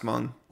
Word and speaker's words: Hmong: It's pronounced Hmong. But yeah, with the H.